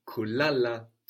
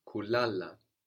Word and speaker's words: kulala: There we have kulala, to sleep. – Kulala. – And we have the accent on the penultimate syllable, on the second last syllable, no? That's where the stress falls. Kulala.